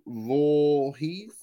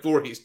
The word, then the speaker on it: Voorhees: The first one I will mention is Andrew Voorhees. Is that how you pronounce his name? – Like the guy with the mask? Like Voorhees, Jason's brother? Right? There's a Jason Voorhees, the guy with the big mask, right? – I don't know if you lost me here.